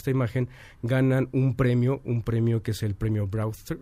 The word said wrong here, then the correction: Breakthrough: me Premio Breakthrough. – Breakthrough. Tengo ese problema en la pronunciación.